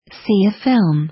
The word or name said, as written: see a film